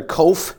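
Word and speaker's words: qof: The Greek Hebrew letter kof. It's the best way to pronounce it, or at least closest.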